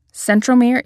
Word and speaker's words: centromere: Centromere is the middle point connecting the two sister chromatids. Okay, centromere, and I put emphasis on the M, mere, M as in middle.